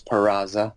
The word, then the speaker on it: Peraza: A lot of people don't even know how to pronounce Peraza. Peraza or some other form of saying it. But I like the way you roll the R's there.